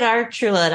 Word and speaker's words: Archuleta: David Archuleta, uh-huh.